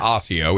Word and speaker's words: Osseo: Over you mean Osseo? Okay, yeah. – Osseo is how it's pronounced.